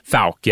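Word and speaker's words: Fouke: Yeah, and it encourages mispronunciation, like a few other names. But Falk, yeah.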